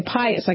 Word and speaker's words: Pius: Aren't we all pious people? Right. Okay, Pius. I got to say that correctly.